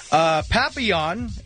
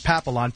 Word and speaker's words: Papillon: Papillon, which I confirmed with Bean is pronounced that way, not Papillon.